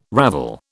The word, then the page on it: ravel: British English